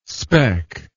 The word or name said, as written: speck